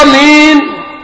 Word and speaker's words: آمين: آمين تنطق هكذا بقصر الهمز المد بدل إلا ورش، ورش بمد البدل، لكن احنا على حفص يبقى مد بدل مثل ما تقول آمنوا، فيبقى مين والياء عارض للسكون، اثنين اربعة ستة انت حر، انما امين إنما الناس اللي يسمعوا الإمام يقول إيه؟